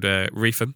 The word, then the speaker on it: Reepham: Actually, it was called Reefham, but it's spelled Reepham.